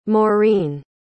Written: Maurine

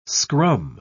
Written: scrum

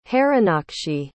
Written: harinakshi

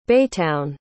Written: baytown

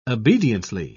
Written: obediently

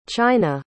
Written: chyna

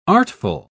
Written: artful

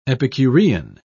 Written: epicurean

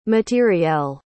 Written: matériel

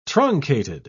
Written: truncated

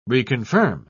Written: reconfirm

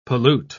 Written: pollute

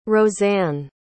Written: roseann

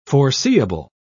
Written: foreseeable

